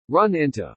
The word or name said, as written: run into